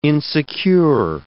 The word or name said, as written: insecure